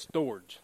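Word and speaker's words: storge (στοργή): S-T-O-R-G-E. Love. Storge love. Now look, when I was in Pomona, California, we pronounced it storge. When I was in Savannah, Georgia, they said storage.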